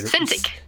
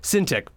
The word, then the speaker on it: Cintiq: He asked, do you find it easier to draw on display tablets like Cintiq? I don't know if I'm saying that right. – Cintiq. – Cintiq, thank you.